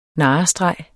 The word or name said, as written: narrestreg